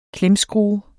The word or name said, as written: klemskrue